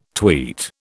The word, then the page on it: tweet: British English